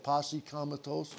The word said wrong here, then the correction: posse comitatus: Posse comitatus.